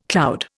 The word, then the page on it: cloud: Indian